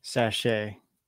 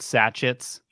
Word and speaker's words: sachet: Did I pronounce that wrong? – Sachet. – Yeah, sachet. – They're called sachet. – Yeah. – Not sachets.